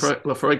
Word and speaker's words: Laphroaig: Yeah, it is Laphroaig Quarter Cask, which is spelled L A P H R O A I G. Yep. – That's one of the easier ones to pronounce, actually.